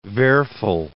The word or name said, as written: Werfel